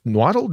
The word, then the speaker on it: Nahuatl: Yeah, it's nahuatl. Nahuatl? Did I say that right? – Nahuatl. – Nahuatl for cooked agave. – Correct.